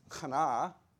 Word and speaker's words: Chana: Anna, it's the same name as Hannah, which is Chana, which means to be favored.